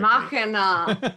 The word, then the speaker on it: Machina: Machina!